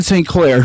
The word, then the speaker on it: St. Clair: My name is Giad St. Clair.